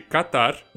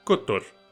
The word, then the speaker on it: Catar: a pronúncia correta do país não seria com a letra A aberta, mas sim fechada. Ao invés de Catar, o ideal seria falar Qatar.